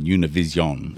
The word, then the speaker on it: Univision: And if you want to watch it in Spanish, it will be live on Univision. Is that how you say it? – Exactly.